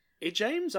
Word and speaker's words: Ijames: Ijames.